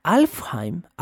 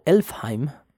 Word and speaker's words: Alfheim: Alfheim, Alfheim Elfheim, también pronúnciese, es la casa de los elfos claros.